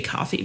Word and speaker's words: Ko-fi: Um, and I, I, know it's supposed to be coffee, but I swear it's spelled Ko-fi.